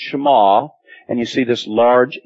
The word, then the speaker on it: Shema: And so it could possibly be written that way and it would still be pronounced Shema.